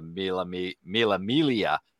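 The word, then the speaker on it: Mille Miglia: they weren't that close on the Mila Milia. There's no G pronounced, and I learned that from John Kleinert. He straightened me out on that.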